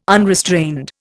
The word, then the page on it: unrestrained: Indian